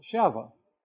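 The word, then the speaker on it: Sheva (שבע): In the Hebrew, it's spelled Sheba, pronounced Sheva. Very similar in pronunciation to our word seven.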